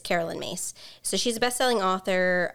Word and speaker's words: Myss: Her name is actually spelled Caroline, but she goes by she pronounces it Carolyn, and Mace is actually M-Y-S-S, in case anyone wants to look her up.